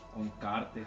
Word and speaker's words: Uncharted: Uncharted.